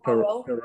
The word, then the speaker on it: Poirot: how do you pronounce it? – Poirot? Yeah.